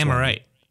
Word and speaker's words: Amirite: But it's spelled A-M-I-R-I-T-E. It's common internet. – Oh, Amorite. – Amorite? – Amorite. – Yeah.